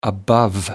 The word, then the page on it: the above: American English